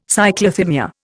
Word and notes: Cyclothymia: Indian

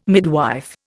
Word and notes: midwife: Indian

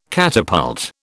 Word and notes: catapult: British English

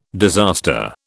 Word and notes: disaster: British English

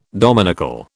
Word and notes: dominical: British English

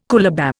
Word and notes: coolabah: Indian

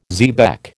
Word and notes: xebec: American English

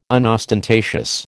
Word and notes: unostentatious: American English

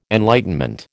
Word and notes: enlightenment: American English

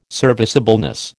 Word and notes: serviceableness: American English

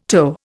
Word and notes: toe: Indian

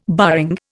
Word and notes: barring: Indian

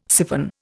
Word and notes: syphon: Indian

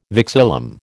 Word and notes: vexillum: American English

